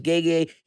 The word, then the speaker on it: Gege: Okay, so I thought it was Gege, but it's Gege.